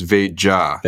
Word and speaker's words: Veja: Yeah, it's Veja. – Veja. Okay. – Because it's Portuguese and you'd pronounce the J.